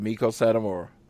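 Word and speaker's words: Meiko Satomura: We had, and I'm going to not say this right, Miko Satomura. – That's exactly how you say it, Miko Satomura.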